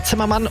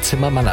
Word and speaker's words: Zimmerman: Mich gerade gefragt, wie Zimmermann eigentlich auf Englisch ausgesprochen wird. Zimmerman. Finde ich mal noch raus. Zimmermann ist aber ansonsten auch absolut korrekt, weil es wird... Nee, es ist Zimmerman.